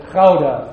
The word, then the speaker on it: Gouda: The Dutchman pronounces it Gouda from deep in his throat. That's hard for a non-Dutchman to pronounce.